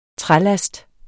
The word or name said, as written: trælast